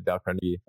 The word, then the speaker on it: Dale Carnegie: Dale Carnegie, actually is how you say it, Dale Carnegie, How to Win Friends and Influence People.